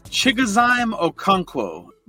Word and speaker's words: Chigozie Okonkwo: It's Chigozie Okonkwo, but I like to call him Chig Okonkwo.